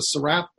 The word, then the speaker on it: serape: He has to take down a rainbow pen, eyeglasses, and mouse pad, and, how do you say this, a serape.